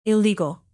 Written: illegal

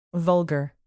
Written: vulgar

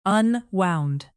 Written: unwound

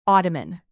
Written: ottoman

